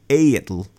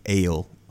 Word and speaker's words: Egill: ale's name is E-G-I-L-L. So it should be a-il. Or some variation on that. Mm-hmm. But we have anglicized the L part for just clarity. – Right. – So it's the rules of pronunciation of modern Icelandic that lead to the pronunciation of ale.